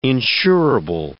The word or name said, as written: insurable